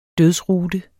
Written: dødsrute

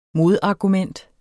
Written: MODARGUMENTERNES

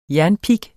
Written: jernpig

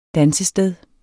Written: Dansesteder

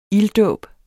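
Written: ILDDÅBEN

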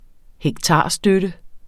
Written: hektarstøtte

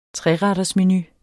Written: trerettersmenu

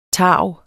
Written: tarv

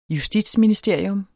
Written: justitsministerium

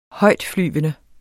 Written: højtflyvende

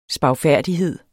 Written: spagfærdighed

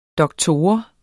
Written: doktor

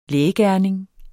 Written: lægeregning

